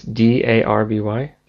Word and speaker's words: Derby: a Derby game. And it's Darby? – DE.